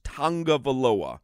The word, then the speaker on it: Tagovailoa: He explains how to pronounce his name, and it is with an N, even though there's no N in there. It is pronounced Tagovailoa.